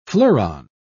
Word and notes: fleuron: British English

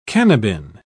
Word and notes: cannabin: British English

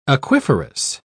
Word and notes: aquiferous: British English

